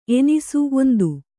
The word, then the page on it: ಎಣ್ಣಿಸು: kannada